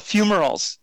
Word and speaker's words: fumaroles: So how is that pronounced? – Oh, yes. No problem. So they're called fumaroles.